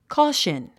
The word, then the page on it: caution: kɔ́ːʃən; コゥシュン